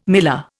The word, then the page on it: miller: Indian